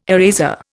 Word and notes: eraser: Indian

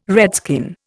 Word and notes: redskin: Indian

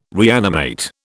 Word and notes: reanimate: British English

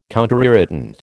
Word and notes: counterirritant: American English

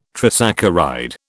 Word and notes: trisaccharide: British English

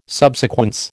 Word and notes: subsequence: American English

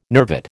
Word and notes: nervate: American English

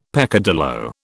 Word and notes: peccadillo: British English

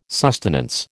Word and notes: sustenance: American English

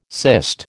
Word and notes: cyst: American English

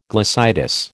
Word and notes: glossitis: American English

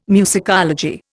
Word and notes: musicology: Indian

